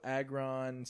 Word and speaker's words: Agron: So Agron sucker punches Crixus.